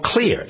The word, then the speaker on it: Xlear: It's called Clear, X-L-E-A-R. That's how it's spelled.